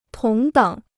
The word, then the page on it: 同等: tóng děng